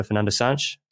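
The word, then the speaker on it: Fernando Santos: They've got a new coach who's come in for Fernando Sanch. Is that right? I pronounced that right as well, Jack? – Yeah. All good.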